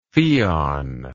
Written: pheon

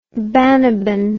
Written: Banaban

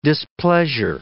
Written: displeasure

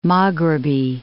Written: Maghribi

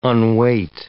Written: unweight

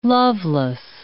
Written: loveless

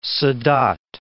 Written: Sa.dat

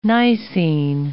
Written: Nicene

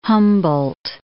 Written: Humboldt

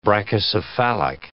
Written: brachycephalic

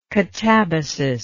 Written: catabasis

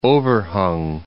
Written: overhung

